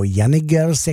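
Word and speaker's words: Janick Gers: a ještě samozřejmě Jenny Gers, nebo Jenny Gers, jak jsem taky postřehl, že se vyslovuje.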